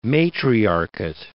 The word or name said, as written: matriarchate